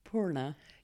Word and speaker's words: Poorna: Poorna.